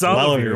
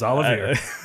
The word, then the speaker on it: Olivier: Elevator. Salivier. I can't say X. – It's Olivier. We'll get it by the end. – Whatever. – It's Olivier. – I'm sorry I butchered his name so hard.